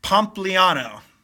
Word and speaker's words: Pompliano: His name goes by Anthony Pompliano.